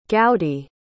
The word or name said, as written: gowdy